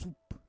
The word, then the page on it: sup: Roon